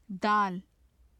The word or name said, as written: dal